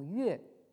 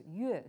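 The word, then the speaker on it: yue (乐): Alors là, vous aurez peut-être un peu plus de mal à reconnaître donc le caractère moderne qui se prononce actuellement yue, et qui en réalité en fait a une double prononciation, c'est-à-dire donc quand on le prononce yue, ça veut dire donc la musique, et on peut également le prononcer le, auquel cas ça signifie donc le fait d'être heureux, hein, le fait d'être joyeux.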